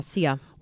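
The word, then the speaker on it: Cea: This is the lady who her name is, I assume it's pronounced Sia. It's C-E-A. How would you pronounce that? – Yeah, Sia. – Like acai? Like I always mispronounce that word, A-C-A-I? – Acai?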